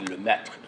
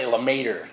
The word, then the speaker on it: Lemaître: I don't know how to pronounce French, but a French physicist friend of mine told me it should be Lemaître or something like that. So I'll just say Lemaître, like a good one.